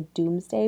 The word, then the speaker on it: Domesday: The other thing he's kind of known for is this thing called the Doomsday Book. Have you heard of this? – Yes. Is that misspelled? – Nope. – Okay. – That is how it is spelled. It looks like Domesday.